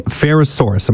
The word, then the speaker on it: Ferrosaurus: Okay, so given all of that, so this is called the Ferrosaurus. Am I saying that right? – Yeah, exactly.